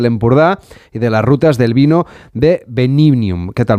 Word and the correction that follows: Benivium: Bueno, tienen ustedes eh, Benivium, perdón que lo he dicho mal.